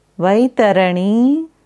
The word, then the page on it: Vaitarani: Sanskrit